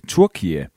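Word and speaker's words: Türkiye: De vil nemlig skifte navn fra Turkey til Turkiye.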